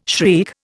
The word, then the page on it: shriek: Indian